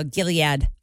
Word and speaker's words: Gilead: Oh, Gilead.